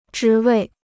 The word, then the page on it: 职位: zhí wèi